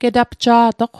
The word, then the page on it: getapja'toq: Mi'gmaq